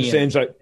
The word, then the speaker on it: Vincennes: the USS Vincennes, if I pronounce it right. – Talking about the Airbus incident? – Yeah. Where we shot down a Iranian. – Vincennes, it was.